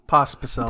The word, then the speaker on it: Pospicel: aka Travis Prospicel. Did I say that right? – Prospicel? – Nailed it. – Nope, there's no R right there. – Pospicel.